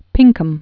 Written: Pinkham

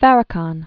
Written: Farrakhan

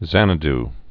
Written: Xanadu

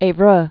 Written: Evreux

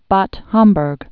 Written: Bad Homburg

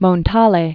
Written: Eugenio Montale

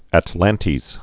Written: atlantes

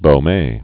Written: Baume scale

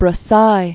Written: Brassai

